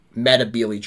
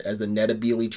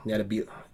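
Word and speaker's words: Ndebele: called them the Matabele, and it's still called Matabele land now, but the way you actually pronounce it is more with an N. N-D-E-L-E-L-E. Nitabele. N-D-E-L-E. I can't spell it. Nitabele. B-E-L-E. N-D-B-E-L-E.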